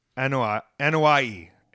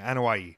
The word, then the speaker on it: Anoa'i: So the Anoa'i family, and Anoa'i is how they are pronouncing it, Anoa'i, is a family of professional wrestlers originating from American Samoa.